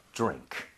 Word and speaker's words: drink: 그래서 드링크가 아니라 이 모양 좀 모아서 drink 이렇게 가는 게더 자연스럽죠. – 와우. – 자, 해보겠습니다. – 네. – drink, drink.